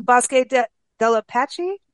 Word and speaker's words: Bosque del Apache: The Bosque del Apache National Wildlife Refuge? – Yeah.